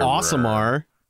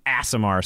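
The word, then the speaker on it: Aasimar: Osimar? – Yeah. I think that's actually how you say it. – It is. – Asimar sounds kind of silly. – Double A usually makes an ah sound.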